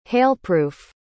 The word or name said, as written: hailproof